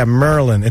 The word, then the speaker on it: Maryland: And I remember the guy say, yeah, Merlin. It's like Merlin the magician. That's how you say it.